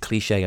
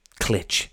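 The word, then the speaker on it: Cliché: I've just remembered something, the little accent above the E in Cliche there. That is what tells you to pronounce it Cliche and not Clitch or Clitchy.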